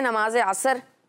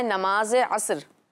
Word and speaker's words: عصر: جی تو پیارے بچوں اس کا تلفظ آج ٹھیک کر دیتے ہیں کہ نماز, کچھ لوگ بولتے ہیں نماز عصر, تو اس کا تلفظ ٹھیک یہ ہے کہ اس کو بولا جائے نماز عصر, یعنی سوت کے اوپر جزم لگا دی جائے نماز عصر.